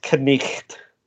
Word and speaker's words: Knight: So the first and most obvious is that we used to pronounce that letter, mm-hmm. but we don't anymore. Like Knie. Yeah, like Knie or Knight. – Knigget. – Knigget. well, it's Knight.